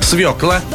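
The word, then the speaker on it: свёкла: Ну, давайте так, как правильно произносить? Давай. Свекла, искра, грушевый, полшага, оптовый. Именно так и никак иначе.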